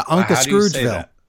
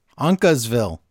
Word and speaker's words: Uncasville: Yeah, Unca Scroogeville. – Uncasville. How do you say it? – Uncasville.